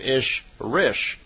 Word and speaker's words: Ishrish: And Semi in their language is E-L-O, so Elo. And a female Ishwish is called an Ishrish, I-S-H-R-I-S-H. So, in effect, Semyasi is an Elo Ishrish, is how they would pronounce it in her language.